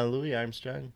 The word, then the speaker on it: Louis Armstrong: Yeah, Louis Armstrong.